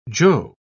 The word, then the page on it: joe: British English